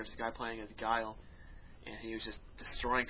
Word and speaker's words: Guile: I sat there and watched, and there was some guy playing it with Guile. That's how you pronounce it. I see people pronouncing the name. – We're going to go over that later. The actual... – calling him Gilly or whatever.